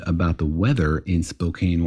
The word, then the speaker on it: Spokane: And this listener, Jeremy, wrote in and said, I just want you to know that Spokane is basically a desert and it's pronounced Spokane.